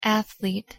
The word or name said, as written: athlete